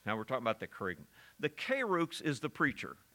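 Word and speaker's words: kerygma (κήρυγμα): You, in the original language, you would pronounce it kerygma. We kind of use an English word that way, not charisma, but kerygma.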